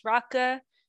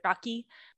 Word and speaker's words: rakı: okay, so I'm going to talk a little bit about rakı, which is the national drink of Turkey, spelled R A K I, pronounced raka. So, you know, I've heard a lot of people pronounce it rocky. – Well, it's because it has the the weird I without the dot.